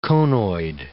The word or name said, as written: conoid